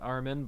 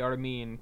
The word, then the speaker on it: Armin: And yes, I know it's pronounced Armin, but I like to say Armin because it's way more fun.